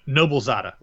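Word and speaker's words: Noblezada: Eva Nosaya? Nosaya? – No, Noble Zada. Noble, and then it rhymes with Nada, I was told.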